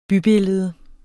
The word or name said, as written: bybillede